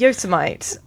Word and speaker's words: Yosemite: How do you say it? – Yosemite.